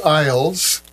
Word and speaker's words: Iles: The soloists were trombonist Alex Iles, that's spelled I-L-E-S, Alex Iles, and pianist Bill Cunliffe.